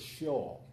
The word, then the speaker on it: Sheol: Universally, the word for hell in Hebrew is Sheol.